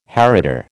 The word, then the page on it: heritor: American English